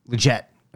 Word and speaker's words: Leggett: Is it Leggett? I don't know if we know how to pronounce it. – Leggett.